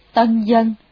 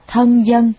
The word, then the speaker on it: tân dân: Tân dân, tuy mặt chữ viết là thân dân, nhưng phải đọc là tân dân, vì thời cổ, tân và thân dùng lẫn nhau.